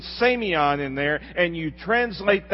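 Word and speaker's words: semion (σημεῖον): The word sign in the Greek language is a word that we pronounce semion, almost like Simeon. Semion.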